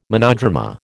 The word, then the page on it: monodrama: American English